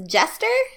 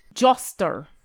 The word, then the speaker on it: Joster: Did you say your name was Jester? – Joster. Joster. Joster. – Like justice, yes. But Joster.